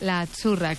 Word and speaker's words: azzurra: La Z no es la Z como decimos en catalán tan sonora, no. Ah, es azzurra. – Muy bonito, muy bonito. – Es como pizza.